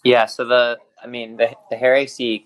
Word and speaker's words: Hare AC: We're partnering with Hare AC.